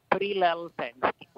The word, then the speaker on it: Prilleltensky: Yes, that was correctly, Prilleltensky.